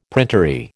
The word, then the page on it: printery: American English